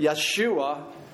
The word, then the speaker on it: Yeshua: And it's that Hebrew word, Yeshua, which is the Hebrew pronunciation of Jesus.